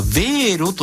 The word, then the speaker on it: Wero: ドイツ公共放送 NDR よりデジタル主権は市民にも、欧州初決済サービス、これはヴェーロと読むそうです、 WERO 広がるということで、これ教えてください。